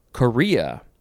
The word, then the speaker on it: chorea: Next we have Korea. It is spelled C-H-O-R-E-A. It is not the country, Korea.